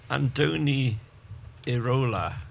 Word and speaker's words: Andoni Iraola: Andoni Iraola.